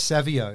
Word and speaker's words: Savio: It all started in the Savio. Is that the correct pronunciation? – Yeah, it's Savio. It's Italian. They're an Italian family, really great family, but they're Savio.